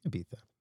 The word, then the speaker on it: Ibiza: Ibiza.